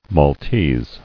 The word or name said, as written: Maltese